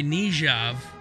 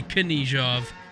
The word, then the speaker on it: Knyazhov: So now, last time we talked about this guy, Nikolai Nijav was what I... was told how it was pronounced. Now they do pronounce the K, because I listened again. So it's Nikolai Knyazhov, first of his career, 36 games played, all but three this season.